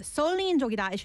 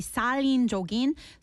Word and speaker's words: 살인적인: And actually, I mispronounced that. So it's, instead of 설린적이다, it should be 살인적인.